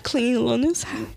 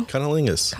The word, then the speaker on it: cunnilingus: Clingalingus? I don't know how to say it now. – Cunnilingus.